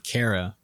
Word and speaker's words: Kara: So the girl who punched John's V-card was a stage combat major named Kara with a C rhyming with air and not R.